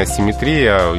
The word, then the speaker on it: асимметрия: Здесь э, ударение асимметрия единственно возможное, и никуда оно не переходит. – Просто есть, запоминаем и никаких гвоздей. – Да, симметрия, но асимметрия. Вот надо запомнить.